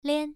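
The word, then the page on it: lian: tone 1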